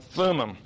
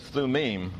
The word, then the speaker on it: Thummim: That's how I've usually heard these words pronounced in English. Hebrew has a tendency to want to stress the final syllable. So in Hebrew, this would really be the Urim and the Thummim.